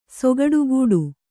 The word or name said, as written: ಸೊಗಡುಗೂಡು